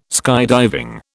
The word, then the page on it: skydiving: British English